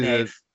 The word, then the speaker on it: Neve: So this is Neve. It's spelt Neve. – Yeah. – But I remember reading an interview with her in FHM way, way back where she said it was Neve. Like Neve, like short for Neville, which...